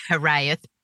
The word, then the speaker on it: hiraeth: And then you mention a Welsh word, and I don't know the pronunciation. – Hiraeth.